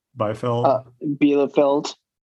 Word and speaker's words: Bielefeld: Bielefeld.